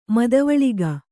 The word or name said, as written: ಮದುವಳಿಗ